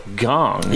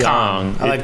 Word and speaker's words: Kang: Jung Ho, and I haven't corrected you till now, Gong. – Yeah, it is weird that it's Gong. – His last name is Gong. – Spelled like Kang. – Yeah, but I like more of a Kong.